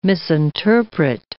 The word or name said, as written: misinterpret